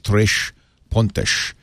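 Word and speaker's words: Três Pontas: We had some Brazilians in the other day, and they said you pronounce it Tres Pontas, from Tres Pontas. But if I say that, you won't know how to spell it. So I'm going to say Tres Pontas, Tres Pontas.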